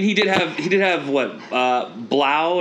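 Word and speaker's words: Blough: But, you know, so it's Blau. It's David Blau. That's how you say it.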